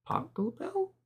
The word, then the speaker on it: Pachelbel: Pachelbel? – Yes, that.